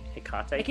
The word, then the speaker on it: Hecate: So triple goddess in Greek system was Artemis, Selene, and Hecate. – Yeah. – Is that how you say it? Hecate? – Hecate.